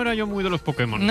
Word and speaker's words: Pokémon: El poder que hay en Pokémon. – Hazte con todos, Pudemon.